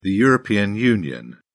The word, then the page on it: european union: British English